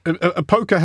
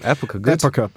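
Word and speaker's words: Época: Say, in, well, a poker has a bright orange. Is that how I'm pronouncing it? – Epoca.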